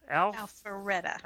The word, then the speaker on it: Alpharetta: Alpharetta.